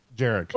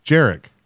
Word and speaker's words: Jarek: Jarek. – Oh, you know what? Is I like that pronunciation. – Is it Jarek like or Eric? – Jarek. – It's Jarek with a J.